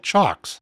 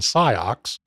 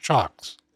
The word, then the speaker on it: CIOKS: It's spelled C I O K S. I did not know how to pronounce it originally, and I used to call them Psyox, but I've been told that the proper pronunciation is, in fact, Chocks.